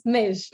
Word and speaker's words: Meigge: Alors juste mon, prénom, ça se prononce Neige.